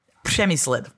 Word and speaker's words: Přemyslid: Přemyslid. so uh, it's a sound that we have in Czech that other people don't have. R with the háček.